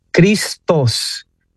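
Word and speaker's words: Cristos (Χριστός): La palabra Cristo desde el griego se encuentra, ¿verdad?, en las Sagradas Escrituras, en la profesión de fe de Pedro, y se pronuncia Cristos, una THOS al final, Cristos.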